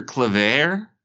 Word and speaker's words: Claver: And he trades Will Barton, Victor Claver.